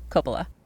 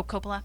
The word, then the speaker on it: Coppola: I think it's Coppola. Coppola. – Oh, Coppola.